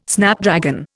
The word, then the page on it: Snapdragon: Indian